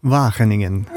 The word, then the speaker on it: Wageningen: It's Wageningen.